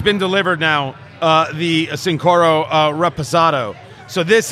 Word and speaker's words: Reposado: We're about to get to the, what are we getting to, the Raspado? – Reposado. – Oh, yes. See, I can't even pronounce it. – Reposado.